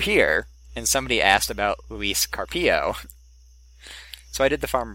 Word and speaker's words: Luis Carpio: It is Luis Carpio. Second syllable is accented in both surname and first name.